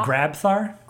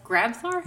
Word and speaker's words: Grabthar: By Grathmar's hammer, right? – right. – Wait, how does it? How is it pronounced? – Uh, Grabthar. – Grabthar.